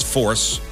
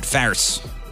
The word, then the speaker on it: Force: I believe it's pronounced Force. That's a St. Louis way of saying it. Farce.